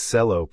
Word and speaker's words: Cello: she said and continued with, "It is Cello, pronounced with a C and not a ch sound, if you don't mind."